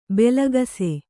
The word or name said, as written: ಭಾಳಾಕ್ಷ